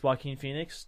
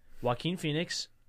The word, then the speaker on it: Joaquin Phoenix: Joaquin Phoenix, that's how you say it, right? – Mm.